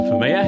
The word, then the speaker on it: Femia: Hello, Melissa Femia.